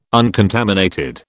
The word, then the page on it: uncontaminated: British English